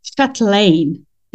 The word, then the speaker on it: Chatlein: Today, my guest is Arlette Chatlein.